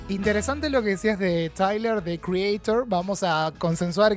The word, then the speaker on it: Tyler: ¿Es Taylor? ¿Tyler? – ¿Cómo se pronunciará? Creo. Tyler. Tyler. – Porque, yo dije Taylor porque como no es Swift y se escribe sin la A, dije no sé.